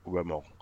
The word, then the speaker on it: roman: So it's from the French word for novel, which is spelt Roman, but Roman. So it's actually Roman porno, but because they're stories that are pornographic rather than romance.